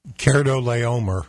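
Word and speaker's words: Chedorlaomer: Kedorlaomer.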